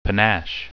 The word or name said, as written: panache